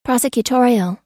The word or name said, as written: prosecutorial